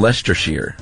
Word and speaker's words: Leicestershire: My free tip for you is that if you ever have to discuss a place called Loughborough in Leicestershire, it's pronounced Loughborough in Leicestershire.